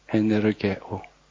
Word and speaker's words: 에네르게오: 헬라어로 에네르게오라고 발음합니다. 에네르게오.